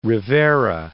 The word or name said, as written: Rivera